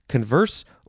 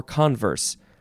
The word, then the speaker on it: converse: Next word is the fourth form of converse, and this can be uh, em- either syllable can be emphasized converse or converse.